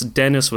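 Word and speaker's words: Denis: but Denise, it's Dennis with one N, Denise Levant, that actor, I think he's unimpeachable.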